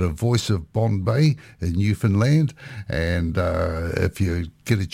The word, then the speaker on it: Newfoundland: my friend there, Clayton Hand, gave me a little bit of help and said, you got to pronounce it Newfoundland with the emphasis on the land.